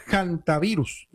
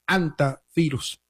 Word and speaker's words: hantavirus: Creo que se refiere a la palabra hantavirus, y la palabra hantavirus no es una palabra en castellano, por eso se pronuncia como se pronuncia. Es una palabra que apareció en Corea hace bastante tiempo. Créame que me he tomado la tarea de buscar la pronunciación. Se podría pronunciar también con la H muda, por supuesto, y también es válida la pronunciación haciendo el énfasis en la primera sílaba con la letra J de Hanta, aunque también es válida la forma hantavirus.